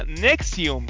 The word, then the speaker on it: NXIVM: Y es que NXIVM se pronuncia Nexium.